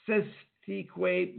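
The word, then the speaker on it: cestui que: Pronounced Sestique, but the Lord's probably pronounced it Sestique from the Old French.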